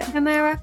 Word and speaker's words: Shu Uemura: So I'm going to talk about the Shu Uemura. Is that how you say it? – Shuemura.